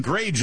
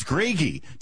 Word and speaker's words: Gragey: All this time, I pronounced his last name wrong. That Dustin Grage is doing a great job, and probably half my colleagues are like, who are you talking about? It's Gragey. Dustin Gragey, Minnesota-based journalist, columnist at Town Hall, joins us now.